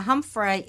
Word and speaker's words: Humffray: Now, Humphrey is spelt H-U-M-F-F-R-A-Y, so it's Humphrey Street South, Golden Point, Ballarat.